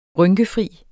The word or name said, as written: rynkefri